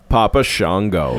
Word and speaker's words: Papa Shango: Papa Shango. – Did I say that right? – Shango? – Shango? – Yeah, that's right. Papa Shango.